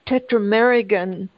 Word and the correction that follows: Tetragrammaton: I know I'm not pronouncing that right. I think John knows the proper pronunciation of it. – Tetragrammaton. – Pardon me? – Tetragrammaton.